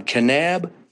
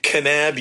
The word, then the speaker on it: Kanab: My friend Walt Prisbury from Kanab, Utah. – Kanab, Utah. – K-A-N-A-B. Yes. – Kind of rhymes with Moab. – Right. Moab, Kanab. Yeah.